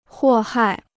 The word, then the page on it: 祸害: huò hài